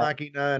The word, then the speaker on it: Ex Machina: Ex Machina and Annihilation.